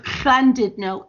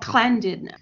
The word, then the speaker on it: Llandudno: I still didn't pronounce it properly, but when I'm, but the thing is, even in North Wales, they've turned the double L into a CL sound. So instead of Llandudno, it's called Llandudno.